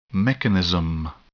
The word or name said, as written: mechanism